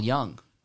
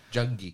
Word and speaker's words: Junge: Paul Young. – Yeah. – Which is J-U-N-G-E. It's like a... – Yeah, Jungie. – Jungie. Like, no, it's pronounced Young. Young.